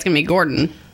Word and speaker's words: Gorton: I don't think he pronounces his name Gorton, but that's how it's spelled. – Oh, whatever. – Why isn't it with a D? It's G-O-R-T-O-N.